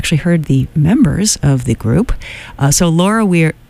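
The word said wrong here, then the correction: Veirs: I do it every single time I want to do the German pronunciation. She's not even German. Laura Veers, The Canyon, from her new album called The Lookout.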